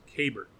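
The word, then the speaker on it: Keibert: So, in February, they sent out a guide with like the proper like accent marks and some pronunciations for players, and for the Dodgers catcher, it says K-E-Y in all caps as the accented syllable, and then B-E-R-T. – Kybert.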